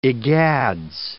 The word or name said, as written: egads